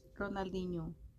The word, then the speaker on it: Ronaldinho: Ronaldinho.